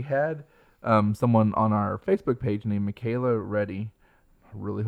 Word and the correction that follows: Reedy: Reedy. It's Reedy. – Reedy, yeah. – R-E-E. I think that's right.